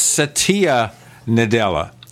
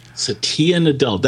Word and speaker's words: Satya Nadella: Well, speaking of kicking around, we're going to have more of Bill Gates, because the new CEO, and I had to look up the New York Times for how to pronounce this. It's Satya Nadella. – Satya Nadella.